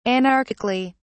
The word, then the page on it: anarchically: British English